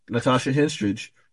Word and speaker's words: Natasha Henstridge: I got mine based off the new character, Natasha Henstridge version.